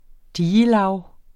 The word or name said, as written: digelag